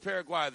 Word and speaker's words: Paraguay: Are we calling it Paraguay or Paraguay? – So, I always pronounce it Paraguay. – Okay, I'll rock with Paraguay then, because it doesn't rhyme with Turkey A and USA, and I'm not a rapper, so I don't need to be rhyming everything.